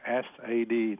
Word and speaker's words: SAD: Và người ta lấy ba cái chữ đầu, người ta viết tắt, người ta ghép lại thành cái chữ SAD, ta đọc là sad.